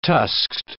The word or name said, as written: tusked